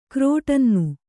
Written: ಕ್ರೋಟನ್ನು

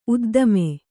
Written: ಉದ್ದಾಮ

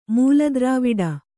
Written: ಮೂಲದ್ರಾವಿಡ

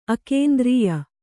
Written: ಅಕೇಂದ್ರೀಯ

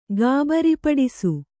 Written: ಗಾಬರಿಪಡಿಸು

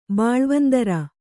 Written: ಬಾಳ್ವಂದರ

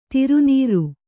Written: ಧಾರೆನೀರು

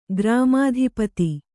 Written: ಗ್ರಾಮಾಧಿಪತಿ

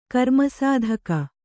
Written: ಕರ್ಮಸಾಧಕ

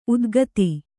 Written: ಉತ್ಖಾತ

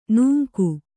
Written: ನಿಂಗ

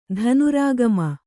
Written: ಧನುರಾಗಮ

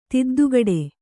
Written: ತಿದ್ದುಗಡೆ

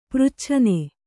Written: ಪ್ರಚ್ಛನೆ

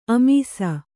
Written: ಆಮಿಸ

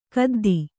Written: ಕದ್ದಿ

